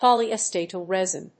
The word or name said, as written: polyacetal resin